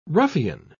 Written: ruffian

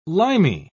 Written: limey

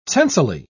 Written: tensely

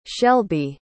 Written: shelbi

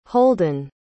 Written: holdin

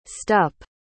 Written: stupp

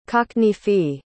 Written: cockneyfy

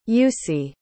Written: jussi

